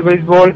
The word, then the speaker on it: béisbol: En México, el béisbol, pronunciado en México sin acento en la E, es el deporte más popular en las regiones norte y sureste.